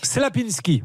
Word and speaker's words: Czaplinski: Alors, pour la prononciation, hein, C-Z-A-P-L-I-N-S-K-I, Damien Klapinski, on va dire, de DC Rénov. – Slapinski, me dit-on.